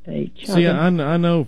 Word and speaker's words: chaga: Oh, I probably have mispronounced every herb you can think of, but it's called chaga or shaga.